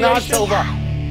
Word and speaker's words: Shana Tova: Shana Tova.